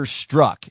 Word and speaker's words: Strzok: Peter Strzok is how you say his name. It's Strzok.